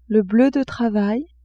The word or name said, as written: bleu de travail